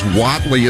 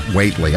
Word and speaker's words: Whatley: Says Whatley is pronounced Whatley, not Waitley.